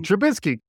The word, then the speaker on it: Trubisky: Trubisky. – Can I get a Trubisky with a hot dog? Can I do you Patrick Mahomes? – Please. – So it's Trubisky.